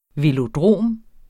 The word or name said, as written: velodrom